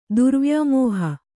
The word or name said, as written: ದುರ್ವ್ಯಾಮೋಹ